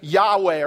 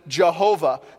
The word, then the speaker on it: Yahweh: In the Old Testament, whenever you see capital L-O-R-D, that is the divine name. That is what we say as Yahweh, or some people pronounce it Jehovah.